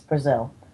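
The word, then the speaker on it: Breazeal: Dr. Brazil, I'm not even kidding, it's Brazil, but it's spelled completely differently,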